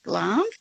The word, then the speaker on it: Glamis: It's called Glam's. It's spelled G-L-A-M-I-S. Glam's, but it's pronounced Glam's thistle.